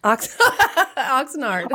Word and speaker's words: Oxnard: Oxnard.